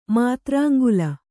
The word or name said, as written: ಮಾತ್ರಾಂಗುಲ